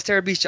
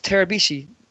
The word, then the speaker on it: Terabishi: How do you say your last name? I'm like, oh, it's Terabishi. Oh, no, you mean Taliban.